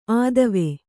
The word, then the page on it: ಆದವೆ: ādave